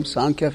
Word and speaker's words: Sankhya: Anybody here heard the term Sankhya philosophy? – Not sure. Can you spell it? S-A-N-K-H-Y-A. Sankhya philosophy.